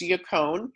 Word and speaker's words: yacon: And it's spelled Y-A-C-O-N. Doesn't even rhyme with bacon, which is sad, but it's yacone.